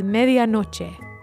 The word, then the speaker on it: medianoche: The Spanish word for midnight is medianoche.